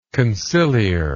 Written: conciliarly

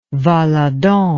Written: valadon

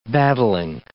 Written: battling

